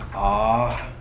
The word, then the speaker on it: Ahava: And I really love the way that even that word sounds, Ahava. You know, that second syllable is just the sound you make when you actually inhale.